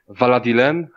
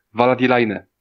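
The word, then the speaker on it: Valadilène: W oryginale to miasteczko się nazywa Valadilen, a w polskiej wersji to jest Valadilaine. No, tak? nie wiem skąd tam jest line na końcu, ale dobra.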